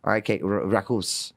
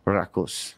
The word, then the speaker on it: Rakkaus: No, R-A-K-K-A-U-S, which means love in Finnish, my native language. – Oh, nice. Rackus. – Oh. – Rackus. So that's how you pronounce it.